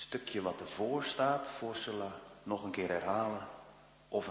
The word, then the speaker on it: Sela: Er staat Sela achter, hè? Of ik moet het eigenlijk uitspreken als Sela.